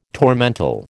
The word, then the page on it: tormentil: American English